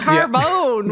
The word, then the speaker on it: carbone: Yeah, carbone, with the emphasis on the bone,